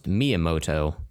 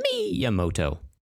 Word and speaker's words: Miyamoto: I believe his last name is pronounced Miyamoto. – There you go. – He's he created Mario, right? – Yeah. – Yeah. So just remember, it's a Miyamoto.